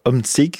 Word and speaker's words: Omtzigt: Miettes, en l'occurrence, un ancien de l'appel chrétien-démocrate, Peter Omtzigt,